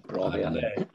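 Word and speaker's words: Brobbey: How do you say that? Is it Brobby or Broby? Or is it like Bobby with an R? – Yeah, Brobby.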